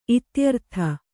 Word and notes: ಇತ್ಯರ್ಥ: ityartha

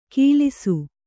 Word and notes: ಕೀಲಿಸು: Kannada

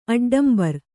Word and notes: ಅಡ್ಡಂಬರ್: aḍḍambar

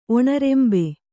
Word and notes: ಒಣರೆಂಬೆ: Kannada

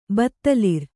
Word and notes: ಬತ್ತಲಿರ್: Kannada

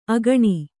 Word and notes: ಅಕ್ಕಣಿ: akkaṇi

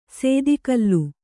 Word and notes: ಸದಾಕಾಲ: sadākāla

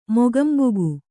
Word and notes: ಮೂಕಾಂಬಿಕೆ: Kannada

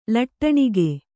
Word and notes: ಲಟ್ಟಣಿಗೆ: Kannada

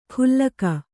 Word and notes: ಖುಲ್ಲಕ: khullaka

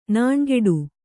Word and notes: ನೊಣಗೇಡಿ: noṇagēḍi

